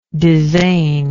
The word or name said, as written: dizain